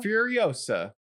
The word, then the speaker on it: Furiosa: Furiosa. Yeah. – How do you say this word? Furiosa? – Furiosa.